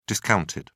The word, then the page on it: discounted: British English